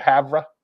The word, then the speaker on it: Havre: EOU football had a huge win this weekend on the road in Havre. Did I say that right? Havre? – It's Havre. – Havre. Havre. Yeah. It's just spelled – it should be H-A-V-E-R.